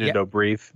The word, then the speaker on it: Dobrev: Nina Dobrev or Dobrev? I know I'm pronouncing Nina Dobrev. – Dobrev, yeah.